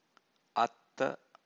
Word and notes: aththa: Pali

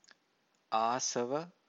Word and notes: asava: Pali

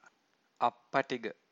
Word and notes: appatigha: Pali

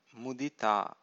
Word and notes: mudita: Pali

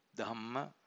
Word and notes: dhamma: Pali

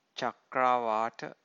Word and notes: chakrawata: Pali

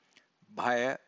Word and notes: bhaya: Pali

